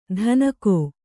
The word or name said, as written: ಧನಕೋ